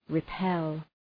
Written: repel

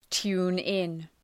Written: tune in

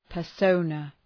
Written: persona

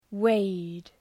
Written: wade

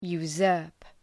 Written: usurp

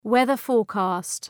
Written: weather-forecast

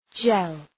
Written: jell

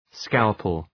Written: scalpel

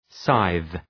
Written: scythe

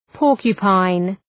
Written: porcupine

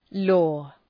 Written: lore

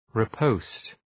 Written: riposte

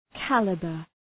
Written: caliber

